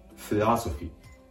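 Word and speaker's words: philosophy: フィラソフィー。もう一度発音します。哲学は英語でフィラソフィーです。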